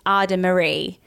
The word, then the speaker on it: Adamaris: So it's spelled A-D-A-M-A-R-I-S. So you don't say the S, it's Ada Marie.